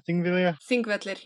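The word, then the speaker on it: Þingvellir: Thingvellir? – Thingvellir, yeah.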